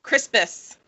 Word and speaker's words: Crispus: I can't even say his name, Crispin? – Crispus. – Crispus? – Literally Crisp and then A-S or U-S.